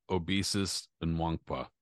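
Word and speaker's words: Obisia Nwankpa: I'll take a stab. Obesus and Wankpa. – Yes, there you go. Perfect before he gets jobbed in a very close fight against Leroy Haley.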